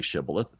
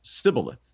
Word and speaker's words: Shibboleth: And so instead of saying, well, they probably thought they were saying Shibboleth, but when it came out, it was Sibboleth.